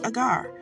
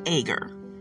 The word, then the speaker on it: agar: Now, I know it's pronounced agar, but when I try to say agar, it just does something to the back of my throat. So it is agar.